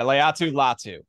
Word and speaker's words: Latu Latu: I think Latu Latu, is that how you pronounce it? Do I have that correct? – Yes, you had it. Latu Latu.